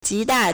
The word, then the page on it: 极大: jídà